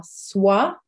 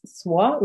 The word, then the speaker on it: sois: So the two forms of être, n'avoir are soit, S-O-I-S, soit, which is être, which is be.